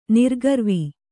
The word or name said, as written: ನಿರ್ಗರ್ವಿ